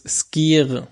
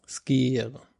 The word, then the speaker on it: Skyr: Skyr wird übrigens Skyr mit 2 Es ausgesprochen. Skyr.